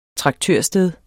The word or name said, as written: traktørsted